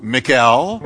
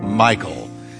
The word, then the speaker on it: Michal: Now, I debated over what to call her, because you can call her Michal, but if you want to go Hebrew, you can call her Michal or Michal.